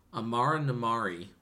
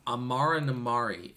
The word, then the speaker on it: Amara Namari: A difference, I think, is that for all of its flaws, Amara Namari... am I saying that right? – I think so. – Amara Namari is a likable character.